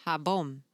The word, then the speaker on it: jabón: The J is jabón. – Pronounced like H always.